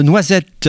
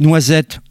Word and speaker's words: Noisette: Alors, quand je dis Noisette à la française, il faut prononcer plutôt, et en anglais, Noisette, petit jeu de mots.